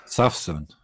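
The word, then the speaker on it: Sawsan: Wie spricht man das aus? – Safsan, ja.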